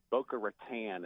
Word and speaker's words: Boca Raton: It's Boca Raton, as if it was some kind of a phone furniture brand or something.